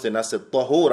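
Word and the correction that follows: طُهور: I said no. Why? That's a whole other word. That means something totally different. What we're talking about is طهور with ضم.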